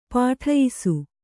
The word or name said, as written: ಪಠಿಯಿಸು